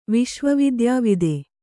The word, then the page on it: ವಿಶ್ವವಿದ್ಯಾವಿದೆ: viśva vidyāvide